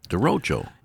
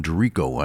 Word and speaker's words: derecho: Derecho. – I think that's how you pronounce it. – I was saying it wrong. I was calling Derico.